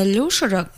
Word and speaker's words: Люшер: Нет, он называет себя Макс Люшер и очень трепетно относится к тому, чтобы его называли правильно.